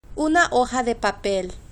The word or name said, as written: una hoja de papel